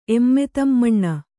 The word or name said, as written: ಎಮ್ಮೆತಮ್ಮಣ್ಣ